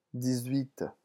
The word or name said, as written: dix-huit